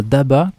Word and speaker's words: Daba: Très bien, donc sur YouTube, on tape tout simplement Daba, comme ça se prononce.